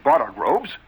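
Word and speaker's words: borogoves: Again, the first O in Bartergroves is pronounced like the O in borrow, borrow. And I've heard people try to give it the sound of the O in worry.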